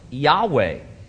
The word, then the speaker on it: Yahweh: Several early Greek writers of the Christian church testify that the name was pronounced Yahweh.